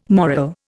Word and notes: moral: Indian

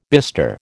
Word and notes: bistre: American English

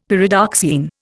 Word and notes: Pyridoxine: Indian

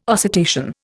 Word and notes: oscitation: Indian

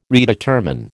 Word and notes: redetermine: American English

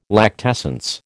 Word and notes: lactescence: American English

